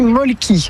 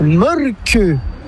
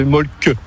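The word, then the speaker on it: Mölkky: Inventé en Finlande en 1996, ce jeu de quilles s'écrit M-O-T-R-E-M-A-L-K-K-Y. – Ça s'écrit molki, mais ça se prononce molke. – Le molki. – Plutôt un E qu'un E, mais pas un U. Donc ça fait molque.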